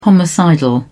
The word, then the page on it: homicidal: British English